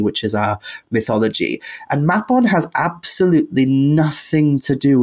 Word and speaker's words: Mabon: I know some people pronounce it. The word in Welsh would be pronounced Mabon.